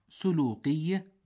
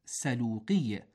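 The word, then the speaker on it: سَلوقي: أيها الأفاضل، نسمع في وصف بعض أنواع الكلاب قولهم سلوقي بضم السين، والصواب سلوقي بفتحها، فهكذا نطقت العرب، لأنه نسبة إلى سلوق بفتح السين، وهي قرية باليمن تنسب إليها بعض أنواع الكلاب والدروع أيضا.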